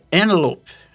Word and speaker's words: Antelope: A pronúncia de Antílope em inglês é Antelope. É um negócio que você fala, meu Deus, né? Não, aparece envelope, né? É mais ou menos isso.